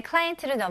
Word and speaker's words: client: 여기서도 강세가 앞부분에 들어갑니다. 그래서 client가 아니고 client. Client. Very good.